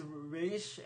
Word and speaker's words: Ra's: Yeah, it was his fault because called it Ra's al Ghul, but that's not how it's pronounced. – Because Nolan knew it as Raish,